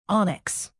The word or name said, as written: onyx